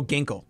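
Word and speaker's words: Ginkel: And we talk about having these 8-9 kind of with what is it, Kegel? How do you pronounce that name? – What? – The D-backs eighth inning guy. – Oh, Ginkle. Yeah. – I don't know where I got K from.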